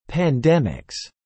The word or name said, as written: pandemics